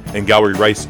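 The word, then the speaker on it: Angourie Rice: Ingauri Rice is the correct pronunciation of her name.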